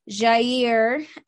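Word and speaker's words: Jaire: Jair Alexander, which if for anyone listening who listened to the previous podcast, let me just point out, I realize I said his name wrong and I apologize. but I made sure to say it right this time. It's right, right? – Jair? You got that right.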